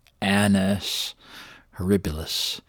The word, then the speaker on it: Annus Horribilis: Careful of the pronunciation there. Annus Horribilis.